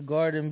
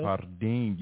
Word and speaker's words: Jardin: Nah, I'm going to just always pronounce it in Spanish. – Because that's how it's pronounced in real life. – Yeah, because that's the name. It's not supposed to be garden.